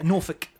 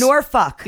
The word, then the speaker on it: Norfolk: Yeah, Norfolk. – Norfolk? – It's Norfolk. – Yeah. – Norfolk? – No, Norfolk. – Say it again. – Norfolk.